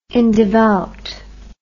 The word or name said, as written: indevout